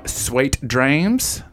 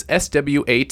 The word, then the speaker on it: Swate Drames: Keeping in this genre, we're going to listen to another track from Meth Dad, his recent album Swate Drames. That is SWATEDRAMES, Swate Drames. I have confirmation that that is how you pronounce it.